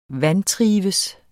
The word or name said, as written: VANTRIVES